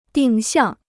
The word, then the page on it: 定向: dìng xiàng